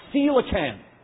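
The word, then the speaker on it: coelacanth: It's actually pronounced a coelacan. Say that with me. Coelacan. Coelacan. Scientists said that this fish went extinct, listen to this, 66 million years ago.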